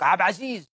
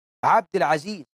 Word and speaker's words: عبد العزيز: وبعده لك عبد عزيز ويلزق اسمه عبد مش عبد، عبد العزيز، عبد الوهاب، ربنا مش اسمه وهاب، قل وهاب مش وهاب.